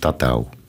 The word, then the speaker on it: tatau: Eh, het woord tatoeage komt vandaan van Nieuw-Zeeland dus, eh, en eh, het woord wordt uitgesproken tatau.